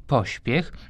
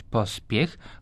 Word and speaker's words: pośpiech: Oczywiście dodajmy jeszcze trzeci wyraz, pośpiech, bo tu nie może być pospiech, chociaż jako ciekawostkę możemy powiedzieć, że dawno, dawno temu wymawiano bardzo tak przesadnie pospiech. Dziś to już tylko pośpiech.